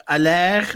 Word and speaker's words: Haller: Nee, ja, bij, bij Ajax, eh, ze hebben, uh, ja, eh, Aller moet je volgens mij uitspreken. Maar goed, in Amsterdam zeggen ze gewoon Haller. hebben ze natuurlijk gekocht. – Dat is op zijn Belgische. hè? Ehm, Haller.